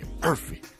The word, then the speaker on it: Arfi: I mean, I've not heard it pronounced like that in a minute. – Really? – My high school Islamic teacher used to call me Arfi. Like exact tone, voice, emphasis, everything.